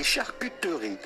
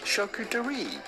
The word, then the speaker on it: charcuterie: Now in French you'd say charcuterie, charcuterie, charcuterie. But in English, charcuterie.